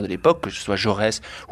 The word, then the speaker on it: Jaurès: And of course, there were those great leaders like Jaurès here. Is that how you pronounce it? – Jaurès.